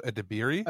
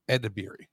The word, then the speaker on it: Edebiri: Io Edebiri? – Ebiri, yeah. I think that's how I've heard it pronounced. – Edebiri.